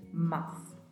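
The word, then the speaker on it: más: Muita atenção para você não pronunciar a letra I quando estiver falando em espanhol, porque não tem a letra I, é mas.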